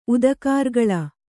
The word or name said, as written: ಉದಕಾರ್ಗಳ